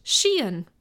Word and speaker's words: Cichan: So it was spelled C-I-C-H-A-N and pronounced Sheehan, apparently.